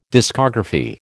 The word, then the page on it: discography: American English